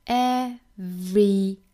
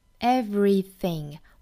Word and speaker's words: everything: 那我们首先来看单词的发音，那第一个单词 everything, 大家就遇到难点了，我们来看这个单词要怎么发 everything, everything, 我们一定要注意这里面有一个小小的 v 的发音，一定要呢上齿咬一下下嘴唇啊，形成一个 v v 的发音。